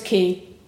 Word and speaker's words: Quay: It's basically, it's like a quay, which is spelled Q-U-A-Y.